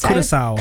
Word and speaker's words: Curaçao: We are moving on now to Ecuador versus Curaçao. Is it pronounced? – Curaçao.